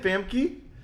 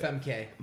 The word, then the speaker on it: Famke: Is that how you say it? Famke? – How do you say it? – Femke.